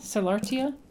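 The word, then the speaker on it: sollertia: You call it prudence, docilitas, and I don't know how you pronounce this. Is it solartia? Solartia? – Solartia, yes.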